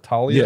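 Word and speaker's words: Taulia: So the from the official number, scouting numbers on do I pronounce it Talia? – Yes.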